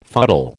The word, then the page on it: fuddle: American English